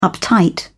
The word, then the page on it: uptight: British English